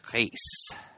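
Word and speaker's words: Reis: It's Brazilian Portuguese, so the R often comes out sounding a little bit more like an H, so like Reyes.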